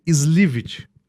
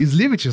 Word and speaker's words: Slywitch: Dr. Eric Slywitch, falei certo? – Falou certo. – Slywitch, acertei? – Slywitch, acertei.